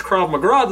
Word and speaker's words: Krav Maga: But I don't think he knew how drunk he was, and I don't think he knew how much Krav Maga the black guy knew. So, did I say that right? – You did. That's cool.